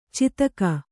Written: ಚಾತಕಿ

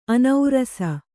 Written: ಅನೌರಸ

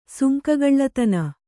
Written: ಸುಂಕಗಳ್ಳತನ

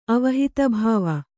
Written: ಅವಹಿತಭಾವ

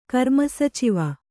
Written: ಕರ್ಮಸಚಿವ

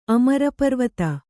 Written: ಅಮರಪರ್ವತ

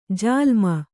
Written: ಜಾಲೀಮ

